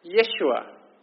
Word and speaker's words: Yeshua: They would have called his name Yeshua, which of course is the same as Joshua. He's a new Joshua to lead the people into a new home, a new victory.